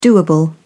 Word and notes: doable: British English

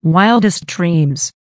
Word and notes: wildest dreams: American English

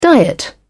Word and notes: diet: British English

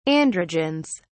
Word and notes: androgens: British English

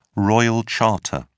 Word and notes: royal charter: British English

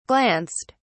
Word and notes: glanced: British English

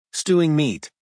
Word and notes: stewing meat: American English

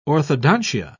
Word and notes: orthodontia: British English

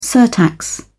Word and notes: surtax: British English

